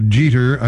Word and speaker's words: Jeter: The band name, Jeter Pillars, J-E-T-E-R, dash P-I-L-L-A-R-S. Well, those are two of the guys in the band. Whether it's Jeter or Jeter, I don't know. Jeter, J-E-T-E-R, looks like it rhymes with Peter, but I knew a family by that name, and they called it Jeter, so I'll go with that.